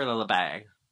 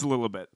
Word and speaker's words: Lilibet: Is it Lilibet or Lilibay? I don't know how to fucking say it. – I like Lilibet, the silent T. No, it's Lilibet. Because okay, it's based on Elizabeth.